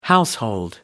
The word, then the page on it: household: American English